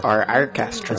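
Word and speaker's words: Arkestra: Our Orchestra. – It's pronounced like our Orchestra.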